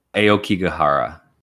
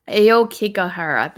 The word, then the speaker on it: Aokigahara: Aoghikehara Forest. Do you know how to say this? – One second. – Aoghikehara Forest. – Aokigahara. – Mm-hmm. Aokigahara. That sounds way better.